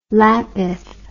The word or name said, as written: Lapith